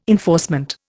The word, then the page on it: enforcement: Indian